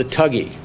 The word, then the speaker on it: thug: And I asked him, well, what about the tuggy? Would the good tuggies qualify under that? He didn't understand who the tuggies were. We use the term thug, T-H-U-G. It's an Indian word. It is pronounced tug.